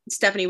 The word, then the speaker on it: Steffanie: My name is spelled Steffanie with two F's,